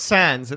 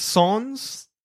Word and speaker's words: sans: How do you say it? It's not Sans. It's Sans. – Yes. – Sans. – We do not pronounce the name of our podcast name correctly. We don't. I know. – But no, that's the French version. Like, we have sans serif and stuff. Like, English pronunciation is different.